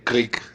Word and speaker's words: Krlic: Pero mi selección de hoy al final es un disco de Bobby Klick. Lo deletreo porque es difícil de pronunciar, es K-R-L-I-C, Click, un músico británico que tiene un seudónimo, que es Hacks and Cloak, con el que publicó algunos discos perturbadores.